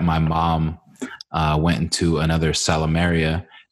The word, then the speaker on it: Salumeria: How do I pronounce? Is it Salumeria? – Salumeria, yeah.